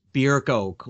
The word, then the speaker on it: Bierko: And like a Craig, I'm probably going to mispronounce his name, Barinko. – He was Bierko.